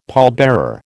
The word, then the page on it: pallbearer: American English